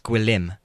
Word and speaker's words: Gwilym: But no, it's Gwilliam, but only two syllables. And actually, I pronounce it wrong. Apparently, it's Gwilym. Gwilym. Gwilym.